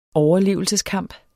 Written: overlevelseskamp

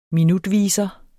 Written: minutviser

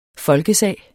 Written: folkesag

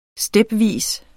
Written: stepvis

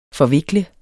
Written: forvikle

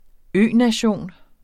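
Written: ØNATION

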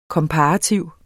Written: komparativ

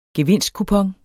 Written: gevinstkupon

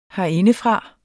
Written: herindefra

